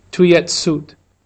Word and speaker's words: tout de suite: Brother Quang remembers Father often said, "Tuyet suit," the Vietnamese way to pronounce the French phrase, "tout de suite," meaning right way.